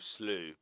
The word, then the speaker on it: slough: And slough instead of slew. But I think that was just my mistake rather than anything. Yeah, so pronunciations.